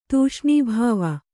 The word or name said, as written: ತೂಷ್ಣೀಭಾವ